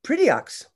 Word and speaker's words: Prideaux: No, but I do remember in the fourth grade, my fourth grade teacher telling me I was pronouncing my name wrong. It was Prideaux. And I was like, no, it isn't. I really don't think that's right.